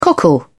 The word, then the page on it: cockle: British English